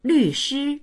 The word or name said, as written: lv4shi1